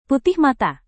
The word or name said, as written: putih mata